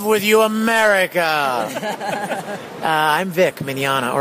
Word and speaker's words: Mignogna: It looks pretty scary to look at all the letters that you don't pronounce. – Right. – It's Italian. You know, those crazy Italians. – Mm-hmm. – They throw a lot of extra letters in there, but it's very simple. Mignogna.